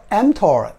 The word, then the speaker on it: mTOR: 这个英文名字叫 Mammalian Target of Rapamycin，简称 MTOR。发音是 mTOR，OK，来我们继续。医学上呢，早已经证明的是，蛋白质摄取太多会造成体内 mTOR 这种蛋白激酶升高，而 mTOR 太高也已经被医学证明会提高致癌的风险。